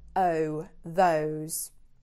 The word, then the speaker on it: those: That and those, completely different vowel sound. Ah, that. Oh, those.